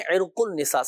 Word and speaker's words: عرق النساء: دیکھیں عرق النساء, صحیح تلفظ ہے عرق النساء, تو ہمارے یہاں مشہور یہ ہے کہ بولتے ہیں عرق النساء عرق النساء عرق النساء, صحیح تلفظ عرق النساء.